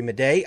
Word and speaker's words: Madej: All right, this morning we have a special guest for you, and her name is Dr. Carrie Madej. I've been saying it wrong, and she corrected me on that.